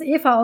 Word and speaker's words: Aoife: Ähm, wobei es dann auch wieder dieses äh, Element gab, was nicht alle so gut fanden, nämlich, ähm, ich weiß, dass es Eva ausgesprochen wird, aber ich weiß das nur zufällig. Es wird ähm, A-O-I-F-E wird es geschrieben. Ich weiß auch, dass es Eva oder Iva ausgesprochen wird. Also Iva.